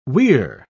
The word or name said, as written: we're